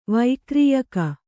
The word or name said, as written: ವೈಕ್ರೀಯಕ